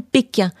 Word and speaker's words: Pequin: On a découvert le nom, l'hôtel Pékin. Alors ça s'écrit P-E-Q-U-I-N, mais évidemment ça fait probablement allusion à la Chine, à la ville de Pékin.